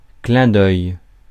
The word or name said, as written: clin d'œil